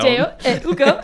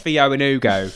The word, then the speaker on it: Théo et Hugo: And there's an accent on the E, and it made me do that. Basically. – But in English, like in an anglicised version, it's like Théo and Hugo.